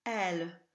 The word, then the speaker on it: aile: These words will rhyme with the word for wing, elle, A-I-L-E, which is not to be confused with bataille and taille. The spelling I-L-L-E is a grouping that becomes the J glide, so there's no L sound in these words at all.